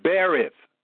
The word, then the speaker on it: berith: In the Old Testament, there is a Hebrew word, This is B-E-R-I-T-H. And I pronounce it Berith. Berith. Berith. B-E-R-I-T-H.